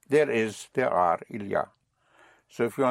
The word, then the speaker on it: il y a: Il y a, spelled I-L, Y, and A. Il y a, but pronounced il y a.